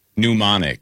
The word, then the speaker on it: pneumonic: Pneumonic. – Pneumonic. – As in pneumonia. – Yeah. Okay. All right. – That's okay, buddy. – Silent P.